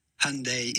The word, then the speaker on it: Hyundai: But that would be essentially Hyundai, Hyundai. Hyundai is essentially how you want to say Hyundai.